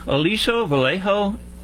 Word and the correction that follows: Aliso Viejo: California. Joseph, did I say that right? – Yes, you did. Aliso Viejo, actually.